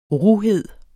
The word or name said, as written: ruhed